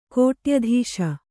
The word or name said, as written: ಕೋಟ್ಯಧೀಶ